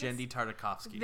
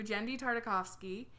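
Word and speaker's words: Genndy Tartakovsky: Jendy Tartakovsky. – Thank you. Jendy Tartakovsky.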